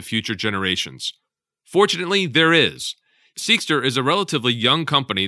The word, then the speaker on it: Seqster: Seekster, it's spelled S-E-Q-S-T-E-R, Seekster, is making an impact on the industry and even being compared to Apple with its ambitious goals and relentless execution.